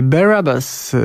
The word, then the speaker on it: Barabbas: Barabbas. Tak się powinno wymawiać z tego, co się dowiedziałem, nazwę tego zespołu.